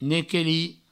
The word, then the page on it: nequelir: nequeli